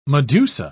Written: medusa

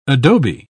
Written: adobe

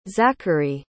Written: zacharee

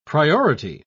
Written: priority

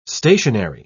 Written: stationary

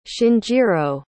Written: shinjiro

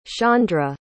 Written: shaundra

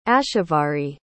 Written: ashavari

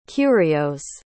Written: curios

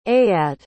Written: eyad

